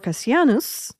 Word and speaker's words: Cassianus: Bishop Cassianus right.